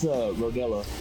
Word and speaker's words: Rodela: And today we are lucky to have with us, Chemo, how do you say your last name? – It's Rodela.